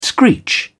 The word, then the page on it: screech: British English